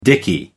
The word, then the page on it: dickey: American English